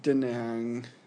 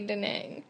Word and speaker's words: Da Nang: Why do you say it like that? I've only heard it be Da Nang. – From the tourists. – Yeah, probably. – I've heard the locals say Da Nang. – Okay, maybe it's Da Nang.